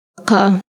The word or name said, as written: qaf